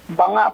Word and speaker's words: Banga: Ito po ba ay Banga o Bangga? – Banga po, Banga. – Banga.